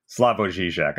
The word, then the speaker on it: Slavoj Žižek: Slavoj Žižek.